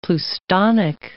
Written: pleustonic

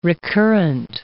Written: recurrent

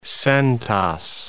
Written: centas

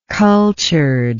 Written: cultured

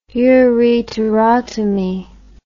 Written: ureterotomy

